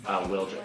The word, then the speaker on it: Wildrick: Wildrick. – Wildrick. I was always saying Wildrick in my own head, but there's no D in there. – There is a D.